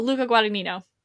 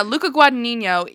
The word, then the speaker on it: Luca Guadagnino: Oh, Luca Guadagnino. – Yeah, Luca Guadagnino.